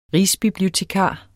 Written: rigsbibliotekar

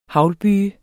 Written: haglbyge

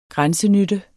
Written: grænsenytte

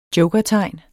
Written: jokertegn

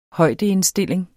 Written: højdeindstilling

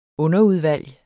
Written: underudvalg

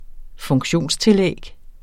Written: funktionstillæg

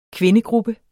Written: kvindegruppe

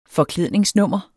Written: forklædningsnummer

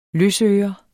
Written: løsøre

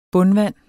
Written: bundvand

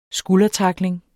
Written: skuldertackling

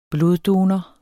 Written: bloddonor

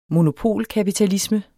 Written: monopolkapitalisme

